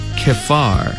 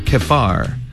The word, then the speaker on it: Kephar: 그 다음에 그발 암모니는 K로 시작해요. Kephar. 그발을, 여기도 PH가 있어요. Kephar. Kephar. 뒷부분은 Ammoni. Ammon, 암몬처럼 생겼어요. 그 다음 I만 붙어 있습니다. Ammoni. 다시 한 번. Kephar Ammoni.